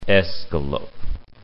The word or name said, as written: escalope